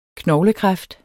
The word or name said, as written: knoglekræft